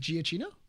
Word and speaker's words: Giacchino: You mean Giacchino? – Yes, that's how you pronounce the last name.